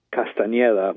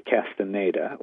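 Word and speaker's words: Castañeda: The correct name, which most people cannot pronounce, would be Castaneda with a tilde over the N. Most people say Castaneda or some variant of that.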